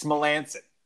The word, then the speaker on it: Melancon: Mark Melancon, you know, a three-time Melancon, Greg. Let's know our new squad. – What was that? – It's Melancon.